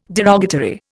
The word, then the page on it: derogatory: Indian